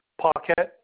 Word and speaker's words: Paquette: Paquette, P-A-Q-U-E-T-T-E.